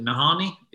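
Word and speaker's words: Nahanni: How do you pronounce it? Nahani? – Yeah.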